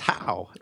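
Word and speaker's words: Haugh: And and apparently uh, the ha unit is pronounced how.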